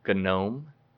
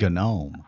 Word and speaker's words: GNOME: You said GNOME. I, I, I figured, you know, I'd better use the proper terminology, but yes, others may say GNOME because it, it, it is spelled like a garden gnome. But uh, the, the first is called GNOME, and then the second one is called KDE.